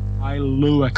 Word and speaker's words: Kailua: So he's a Kalua Crusher. Not Kalua. a Ka- how do you say that one? – Kailua Crusher. – Okay, good.